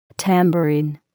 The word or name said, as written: tambourine